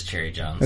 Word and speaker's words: Cherry Jones: She's played by a sheriff, Sherry Jones, and she... – Is it Sherry or Cherry? – Sorry, it is Cherry Jones. Okay. I think so. – It's spelled Cherry.